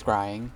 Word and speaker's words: scrying: It's scrying. – It's scrying? Okay. – Yes.